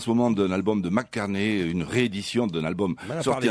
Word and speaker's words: McCartney: Celui-là, McCartney. – McCartney. – Articule. – Ah, excusez-moi, j'ai l'accent de Perpignan. – Il faut absolument que tu prononces aussi les consonnes.